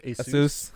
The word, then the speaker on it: Asus: That's properly pronounced Asus.